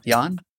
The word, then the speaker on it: Janne: You fucking butchered John Erickson, dude. – It's definitely not John Erickson. Jan? J-A-N-N-E.